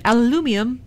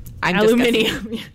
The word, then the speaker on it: aluminium: they named their tables after words that Brits and us Yanks pronounce differently, like potato. – Potato. – Yes. And aluminum. And aluminum. – Aluminium. – Is that what it is? – I don't know. Aluminium, because they added an extra letter or something.